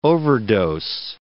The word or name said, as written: overdose